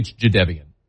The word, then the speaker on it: Jadeveon: Jadeveon Clowney. – Mm-hmm. – He was Jadeveon Clowney forever until he told everybody after we had been calling him Jadeveon Clowney for decades. It's actually Jadeveon.